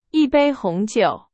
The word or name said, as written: 红酒